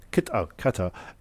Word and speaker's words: Qatar: Qatar is...